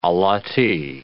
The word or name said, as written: allottee